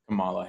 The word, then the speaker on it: Kamala: um, so moving forward yeah camila harris was chosen as biden's running mate um, kamala harris Kamala. I'm sorry, I've been saying that wrong. I thought it was Camila.